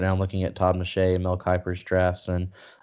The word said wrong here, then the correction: Todd McShay: Let's make sure I'm getting the name right. Yeah, I'm looking at Todd McShay's right now.